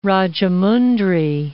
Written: Rajahmundry

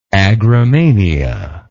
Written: agromania

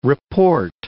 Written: report

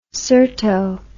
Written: cyrto

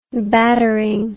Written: battering

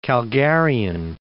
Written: Calgarian

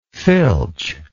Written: filchingly

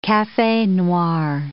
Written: cafe noir

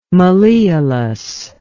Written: malleolus

